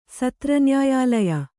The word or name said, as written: ಸತ್ರನ್ಯಾಯಾಲಯ